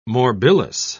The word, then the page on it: morbillous: British English